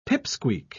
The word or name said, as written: pip-squeak